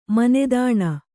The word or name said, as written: ಮಾನಧನ